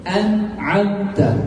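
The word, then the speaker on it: أنعمت: So tawallud is some kind of sound that extra we add on. Some kind of qalqalah. An'amta, that is the mistake. So please clearly pronounce noon an'amta. Please.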